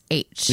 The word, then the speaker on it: Lachish: Yeah, the comb was found in 2016 from Israeli archaeological site Lachish. Or Lachish. I don't know if you pronounce the CH. – Lachish.